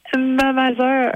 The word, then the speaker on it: mamager: Et le dernier, c'est mamager ou mamager, ou comment on le prononce? – Mamager.